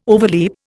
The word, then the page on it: overleap: Indian